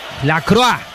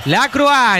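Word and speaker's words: Lacroix: La Croix. ¿Lo digo bien, Agustín? – Perfecto. – La Croix.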